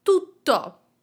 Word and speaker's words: tutto: Let's start with tutto as an adjective and make sure that you are pronouncing that double T in the middle, tutto.